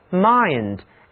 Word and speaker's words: mind: 这里最后这个词 mind, M-I-N-D, 表示心、智慧，跟人的精神有关。且听我来给你们讲解。字母 I 读作 I， mind，这是头脑、理智、精神。